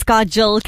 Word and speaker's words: Kajal: So Pannal Lakhani, by the way, my name is Kajal, K-A-J-A-L. You said it right the first time, so just keep it as that. Don't change my name, please.